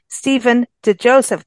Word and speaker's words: Stephen DiJoseph: So I encourage you to go to his website, Stephen DiJoseph.